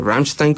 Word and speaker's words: Rammstein: Y pues no sé, en la tercera banda estoy debatiéndome entre Rammstein, o Rammstein como uh-huh. lo pronuncian ellos, y Foo Fighters.